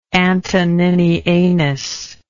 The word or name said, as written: antoninianus